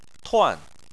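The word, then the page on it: tuan: tone 4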